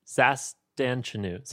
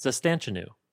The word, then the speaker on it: Zastanchenu: I asked for the phonetic pronunciation. It's Zastanchenu. Zastanchenu.